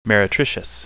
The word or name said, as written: meretricious